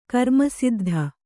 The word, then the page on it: ಕರ್ಮಸಿದ್ಧಿ: karmasiddhi